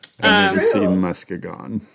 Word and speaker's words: Muskegon: Muskegon? – I guess. – Mm-hmm. I like that we keep asking about the pronunciations. – Is our resident know-it-all about Michigan. – Muskegon.